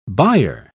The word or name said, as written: byre